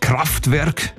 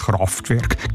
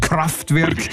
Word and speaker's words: Kraftwerk: Kraftwerk, nicht Kraftwerk, Kraftwerk. ich habe es jetzt falsch gesagt.